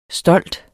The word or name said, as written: stolt